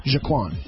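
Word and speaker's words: G'Quan: And I'm spelling it differently, just because otherwise you'll never get the pronunciation right. J-A-K-W-A-N, Jaquan.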